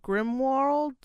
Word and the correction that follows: Grindelwald: It's the Crimes of Geralt Grindelwald. – Grindelwald.